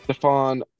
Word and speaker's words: Stephon: Um, Knicks what about Stephen Marbury? – Is that your final pronunciation? Stephon Marbury. You are correct. It is Stephon Marbury.